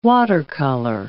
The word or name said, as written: watercolor